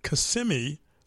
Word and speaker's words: Kissimmee: It was going on in Kissimmee, Florida.